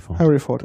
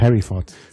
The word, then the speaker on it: Hereford: Und wird in Hereford, UK hergestellt. – Harry Ford. – Oh ja, genau. Harry Ford. – Ja, das ist die Aussprache. Englisch ist ja mal schwierig. Aber es heißt Harry Ford.